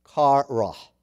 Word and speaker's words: qarah: And it's spelled Q-A-R-A-H. And it is pronounced kara. Kara. The other is a Greek word. And it is spelled K-A-I-R-O-S.